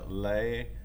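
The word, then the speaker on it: Lae: It's pronounced Lea.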